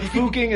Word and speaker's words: Fucking: But Fuching, it's spelled F-U-C-K-I-N-G? – Yeah. – Fuching, huh? – Yeah.